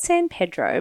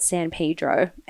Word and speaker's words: San Pedro: So he turned up to board a charter fishing boat called the Freedom in, it's spelt San Pedro, but I read that locals call it San Pedro.